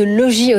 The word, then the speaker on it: Logis: Alors, on va rentrer tout de suite dans le du sujet, pour ne pas dire la feuille de route RSE de Logis Hôtel. On va éviter de prononcer le S, j'ai bien compris.